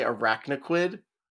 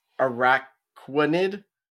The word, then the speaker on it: Araquanid: Ar- I say arachnoquid, but it's Araquanid.